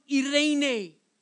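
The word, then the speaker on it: irene (εἰρήνη): The word peace is a Greek word pronounced irene.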